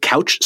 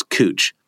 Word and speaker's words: Couch: You know, is it Couch Street in downtown Portland? No, it's Cooch. It's spelled couch, but it looks right.